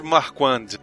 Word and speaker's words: Marquand: Diretor Richard Marken. Quem seria essa pessoa? – Marken? – Marken. Ou Marken. Eu não sei como é que pronuncia. – Richard Marquand.